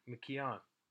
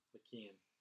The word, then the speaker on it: McKeon: Then we went into your suggested Jack McKeon. – McKeon. – I'll never say it right. – He's never said it right once. – You've nailed it, not saying it correctly. – He is. – Pronounced it every which way but the right way.